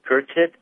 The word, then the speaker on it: Curtit: In pronouncing his last name, Curtit, C-U-R-T-I-T, Dustin, traded in a Toyota for a Chevy Cruze, and he is AM and the PM.